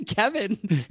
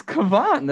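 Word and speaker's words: Cavan: Are guys like Kevon Biggio and Vlad Jr. Just say Kevin. – It's Kevon. That's how it's pronounced.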